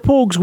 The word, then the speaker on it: Pogues: the Pogues, is that the way that you pronounce it, the Pogues? – Yes, that's right, yeah, the Pogues.